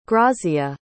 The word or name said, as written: grazia